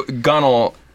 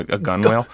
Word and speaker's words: gunwale: So gunnel, this one that's spelled gunwale, but it's, which would be awesome, by the way, a gunwale. – Gunwale. – but it's pronounced gunnel.